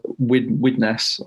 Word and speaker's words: Widnes: I'm not saying it's a bad thing, but you see his resentment grow, because if you're like him, you're from a place, Widnes, I believe is how you pronounce it.